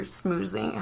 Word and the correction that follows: schmoozing: How do you say that? – Schmoozing.